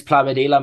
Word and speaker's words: Plamadeala: alex Plamadeala